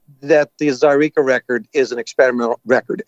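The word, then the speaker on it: Zaireeka: You're coming off of Zyreka, if I'm saying that right. – You are indeed.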